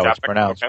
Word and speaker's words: Čapek: And that's Karel K- Čapek, I think is how Čapek, it's pronounced, okay.